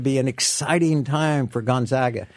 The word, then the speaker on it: Gonzaga: So yeah, we tend to hear Gonzaga a lot, but we want folks to know it's Gonzaga. So think of zag as in bag. And that's the right pronunciation.